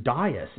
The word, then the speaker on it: Dias: My DD-214 from Operation Desert Shield and Desert Storm, my last name is Dias, D-I-A-S, Dias. My name is always mispronounced as Diaz and often misspelled.